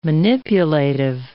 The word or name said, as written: manipulative